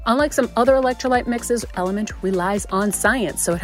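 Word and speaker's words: LMNT: It's L-M-N-T, pronounced Element.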